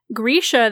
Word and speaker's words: Grisha: And I am an audiobook reader, and so it was being pronounced Grisha the entire time, and my brain totally just did not connect what they were pronouncing as Grisha with the Grishaverse. I was imagining it pronounced like Grecian, as in people or things coming from Greece.